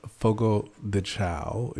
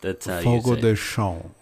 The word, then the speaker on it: Fogo de Chão: It's called Fogo de Chao is how we pronounce it in America. – Unless you're going to criticize them, then don't mention their name. Fogo de Chão. – Fogo de Chão. Yes. Yeah, yes.